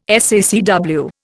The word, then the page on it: SACW: Indian